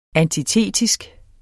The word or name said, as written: antitetisk